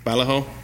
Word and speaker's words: Vallejo: And then Boris, maybe you'll know this, how to pronounce it, Boris Vallejo. – Vallejo, there we go.